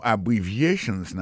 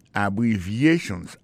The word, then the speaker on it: abbreviations: En parlant de accent, accent tonique qui j'en pour nous prononcer mot yo, n'a pas un mot abbreviations nan qui signifie abréviation. Abbreviations, accent tonique là, tombé sous troisième syllabe là.